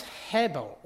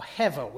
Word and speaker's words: hebel: The conclusion of the writer is all is hebel, or hevel, which is the Jewish word with the Yiddish pronunciation, which means vanity.